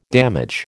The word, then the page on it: damage: American English